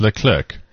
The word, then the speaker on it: Leclerc: Well, because it's French, you like drop the C, don't you? – Well, first tell me. – So it's Leclerc. – How's it spelled? – L-E-C-L-E-R-C.